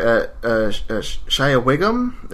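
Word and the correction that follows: Shea Whigham: Is that his name? – Shay Wiggum. – Shay Wiggum, thank you.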